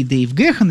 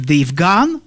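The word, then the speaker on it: Dave Gahan: Безусловно, все те, кто остались сейчас втроем, и Алан Уайлдер, и, конечно же, автор большинства их хитов Мартин Ли Гор и Дэйв Гэхан, или как его иногда фамилию произносят, Дэйв Ган. Ну, мне почему-то Гэхан больше нравится.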